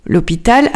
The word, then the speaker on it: hôpital: D'abord, remarquons le H, qui est un H muet. C'est pourquoi on pratique l'élision, l'hôpital avec un L apostrophe. Son origine est latine et s'écrit d'abord sans H. Hôpital avec un O directement, O-P-I-T-A-L.